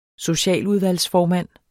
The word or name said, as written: socialudvalgsformand